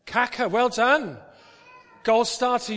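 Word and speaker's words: Kaká: But because nobody can say that, nobody can remember that, people know him as Kaka. Accent on the second A.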